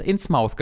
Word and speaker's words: Innsmouth: Innsmith? Innsmith, genau. Das übrigens Innsmouth genannt wird tatsächlich.